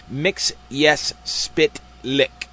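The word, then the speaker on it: Mxyzptlk: that Mr. Messias Spitlick's name be pronounced as Paul Dini and Bruce Timm's themselves announced it, Mix-Yes Spitlick.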